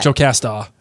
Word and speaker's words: Jocasta: Jocasta. Like Marta, but Jocasta.